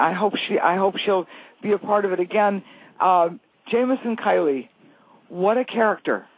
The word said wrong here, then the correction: Keeley: Did I pronounce his name? – Keeley.